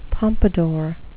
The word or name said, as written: pompadour